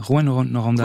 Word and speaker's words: Rouyn-Noranda: Rouyn-Noranda, je prononce bien Rouyn, c'est ça, hein, Rouyn-Noranda. – Oui, exactement, voilà. C'est très très bien.